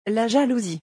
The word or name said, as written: La jalousie